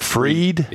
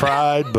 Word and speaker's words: Fried: And Max Fried, F-R-I-E-D, yeah. – Did you pronounce that Fried? – Yeah. – Or it looks like it would be pronounced Fried, but it's Max Fried will be on the mound for Atlanta.